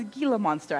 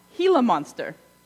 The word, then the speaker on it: Gila monster: I always thought it was Gila monster. I learned that I was pronouncing it wrong. Gila monster.